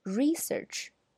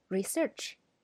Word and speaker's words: research: 它的名词形式，刚才我读的那个要读 “research”，那么动词形式呢，则要读 “research”。